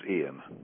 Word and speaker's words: Ian: Is it pronounced Ian or Ian? – It's pronounced Ian.